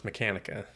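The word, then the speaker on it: Mechanika: Lady Machina? Am I saying that right? Machine? – Machina. – Okay. – No, I'm sorry. No, it's Mechanica.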